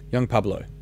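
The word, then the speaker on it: Yung Pueblo: Y-U-N-G, which sounds like young, and then P-U-B-E-L-O, Pablo. Young Pablo.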